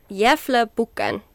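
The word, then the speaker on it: Gävlebocken: So we have the, Jäflebukken. Did I say that correctly? – Yeah. – It's acceptable.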